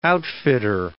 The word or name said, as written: outfitter